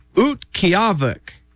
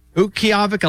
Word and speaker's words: Utqiagvik: Utqiagvik. Utqiagvik, Alaska.